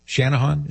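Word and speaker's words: Channahon: Chanahan, Illinois. Shanahan, is that right? – That is correct.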